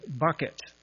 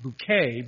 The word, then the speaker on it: Bucket: The main character in the show, her name is Hyacinth Bucket. But she tries to pronounce it bouquet because it sounds more sophisticated.